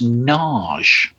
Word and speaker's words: Nagy: And I thought, is it a Hungarian name and it's pronounced Naj? – Yes, I believe it is.